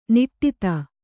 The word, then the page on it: ನಾಡಿದು: kannada